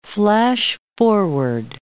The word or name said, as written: flash-forward